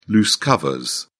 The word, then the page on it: loose covers: British English